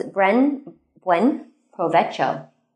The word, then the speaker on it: buen provecho: My first one was buen provecho.